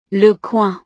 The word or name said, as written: le coing